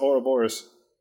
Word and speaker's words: Ouroboros: I think it's pronounced Ouroboros. – It's pronounced Ouroboros.